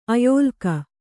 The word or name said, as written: ಆಯಲ್ಲಕ